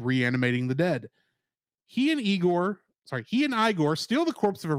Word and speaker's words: Igor: Hearing that the professor pronounces his name Frankenstein, Igor insists that his name is pronounced Igor rather than the traditional Igor.